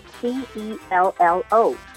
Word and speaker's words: cello: 那像这些乐器呢，其实很多都是意大利文翻译过来的，尤其是这个字，它虽然是 C E L L O，但是它的发音要念 cello。